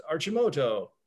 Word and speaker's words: Arcimoto: It has the Italian pronunciation is Arcimoto.